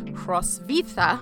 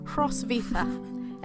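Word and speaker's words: Hroswitha: But the Latinized pronunciation of her name is Hrosvita. – All right. – And she wrote in Latin and wrote her name that way. So that's how we're going to pronounce her name today.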